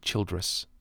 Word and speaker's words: Childress: Childress, yeah. C-H-I-L-D-R-E-S-S. Childress, yeah.